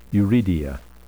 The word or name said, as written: uredia